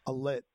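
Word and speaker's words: Eilat: It's spelled like Elite, almost like Elite. Alit, it's called.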